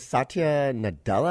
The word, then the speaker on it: Satya Nadella: Yes, his name is Satya Nadella, I think is the pronunciation. – Yeah.